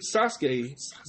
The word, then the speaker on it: Sasuke: Wrapping it up with today's date, August 5th, in 1996, the great Sasuke, I forget, you don't pronounce the U. The great Sasuke beat Ultimo Dragon to win the J Crown.